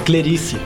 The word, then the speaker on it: Clerice: é, qual a pronúncia? É Clerice. Clerice.